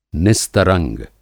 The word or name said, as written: निस्तरंग